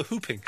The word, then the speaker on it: whooping: No, whooping. You don't pronounce the W.